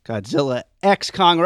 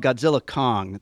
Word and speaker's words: Godzilla x Kong: We're about to talk about this movie we just watched, Godzilla X Kong, or as I'm being told, it's called Godzilla Kong. The X is silent.